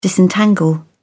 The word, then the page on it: disentangle: British English